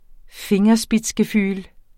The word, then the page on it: fingerspitzgefühl: ˈfeŋʌsbitsgeˌfyːl